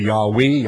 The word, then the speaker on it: Yahweh: And the capital letters L-O-R-D, I've explained before, come from J-H-V-H or Y-H-V-H. There are no vowels, only consonants. That's where they get the word Jehovah, and we're not certain that that is the pronunciation or that it should be pronounced. It could be Yahweh, Yahweh, Yahvah, or some other pronunciation no one knows for sure, but it is the name of God.